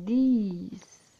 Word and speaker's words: these: What are these? Perceba que é diferente, ó. This é uma coisa só, a pronúncia. This. Mais duas ou mais coisas, these. A pronúncia é diferente.